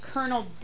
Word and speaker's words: colonel: Without Mercy is by Colonel David Hunt and R.J. Pinheiro. – It's pronounced colonel. – It's pronounced colon. L.